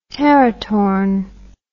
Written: teratorn

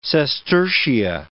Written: sestertia

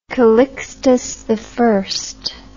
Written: Calixtus I

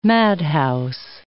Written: madhouse

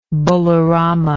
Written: balarama

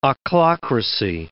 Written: ochlocracy

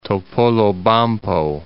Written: Topolobampo

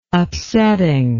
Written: upsetting

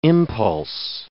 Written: impulse

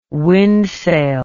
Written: wind sail

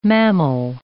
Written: mammal

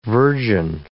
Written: virgin

